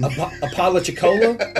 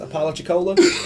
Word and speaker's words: Apalachicola: Apalachicola. I want to say that's how it's pronounced. Apalachicola.